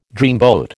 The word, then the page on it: dreamboat: American English